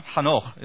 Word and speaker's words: Hanoch: The second one, Hanoch is the Hebrew pronunciation.